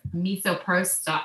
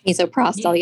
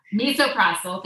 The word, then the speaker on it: Misoprostol: I had to take more surprise Misoprostol. Oh my god, I'm not saying it right. Misoprostol. Yeah. – Misoprostol. Thank you.